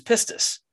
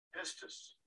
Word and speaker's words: pistis: And so you click on Strong's number, and up comes this word faith, which in the Greek is pistis. And if you don't know how to say it and you want to impress your friends, you can actually have somebody pronounce it for you. Pistis.